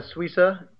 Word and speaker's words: Suissa: So my name is Ben Suissa. You can find me on Twitter at bsuissa. That's B-S-U-I-S-S-A for the name that you can never pronounce and spell.